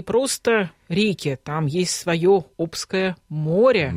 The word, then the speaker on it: Обское: И вы все верно сказали, у нас есть Обское море, Обское, Обское. Все говорят по-разному, но правильно говорить Обское.